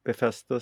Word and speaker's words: Bethesda: Jag vet inte, hur säger man det? Bethesda. – Jag som är mästare på uttal ska ju inte... – Nej, hur säger du det? – Bethesda, säger jag. – Ja, det lät ju bra. Det lät, ja. Det lät bra.